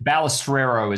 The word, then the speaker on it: Balestrero: Yeah, Balestrero is the way it's pronounced. – Balestrero. Yeah. Okay, I should get that right because it's it's an Italian name.